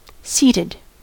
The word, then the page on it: seated: American English